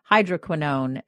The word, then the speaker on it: hydroquinone: And one of them is hydroquinone. And I said it right. And if people listen to our first podcast, they know that it took me a long time.